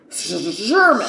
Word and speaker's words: Szurma: It's Sermon, I think, but it's an S-Z-U-R-M-A. So it's Sherman.